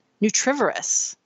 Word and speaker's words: nutrivorous: And I think that what I like about this sort of nutrivorous, ooh, how would you pronounce that? Nutrivorous, right? Omnivorous, herbivorous.